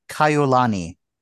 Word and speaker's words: Kaiulani: And I think Erin actually kind of has the right sort of pronunciation or, you know, the meter of the name. It's Kaiulani.